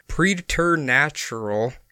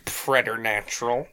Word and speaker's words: preternatural: There is also preternatural combustion, which hypothesizes that a human body can be. – If I may, it is pronounced preternatural.